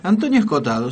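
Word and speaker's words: Escohotado: A ver si lo pronuncio bien a este apellido. Antonio Escotado. – Antonio Escotado, sí.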